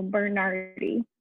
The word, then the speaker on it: Bernardi: It is Bernard with an I, right. So Bernardi.